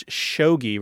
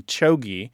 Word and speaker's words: chogi: I see in one of my war talk dictionaries, my military slang dictionaries, that it's listed as pronounced as shogi rather than chogi. But I could see how those pronunciations, one would blend into the other. And it's usually spelled C-H-O-G-I.